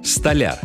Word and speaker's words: столяр: Одно из них – столяр. Да, говорить нужно именно так, с ударением на последний слог.